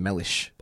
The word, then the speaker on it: Mellish: It is a weird name, Mellish. I don't even know what that sounds like. Actually, that's really hard to kind of place. Well, yes, it sounds like relish. It rhymes with relish.